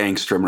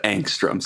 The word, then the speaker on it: Angstrom: If it helps, you did say Angstrom right, I think. Yeah, I think you might have overpronounced the O a little bit, but my chemistry teacher always said Angstrom, so that's what I'm going with.